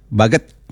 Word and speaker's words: Baguet: Karena saya tadi cari-cari nih tulisannya kan bahasa Baguet ini bacanya apa ya Baguet ini ya? Baguet atau apa? Sampai saya cari di translate Google ternyata Baget bacanya begitu ya Bu Tasya.